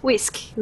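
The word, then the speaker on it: uisce: Até hoje, na língua irlandesa, água a gente escreve U-I-S-C-E e pronuncia como? Desculpa, whisky, né?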